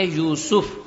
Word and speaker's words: یوسف: مثال کے طور پر قرآن مجید میں ایک نبی کا نام ہے یوسف، یا کے اوپر پیش ہے وا، پھر جو ہے جزم ہے اور سین کے اوپر بھی پیش ہے یوسف، اور ہم بچوں کا نام رکھ دیتے ہیں یوسف، یا یوسف، غلط.